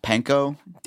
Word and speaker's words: panko: Have you ever used I think it's pronounced panko? – Yeah, panko crumbs, yeah.